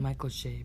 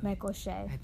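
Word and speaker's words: Michael Che: It's Michael Shea, by the way. – Michael Shea.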